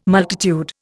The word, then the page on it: multitude: Indian